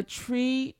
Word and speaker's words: Twi: the tree, T-W-I is pronounced tree. – Yes, tree. – Like you have to do something with your mouth. – It's you gotta purse like your lip and then like kind of spit it out.